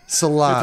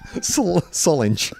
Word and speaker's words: Solange: Rhymes with orange. Sort of. No, it doesn't. – Solange. – If you mispronounce orange, yes. If you mispronounce orange. Solange.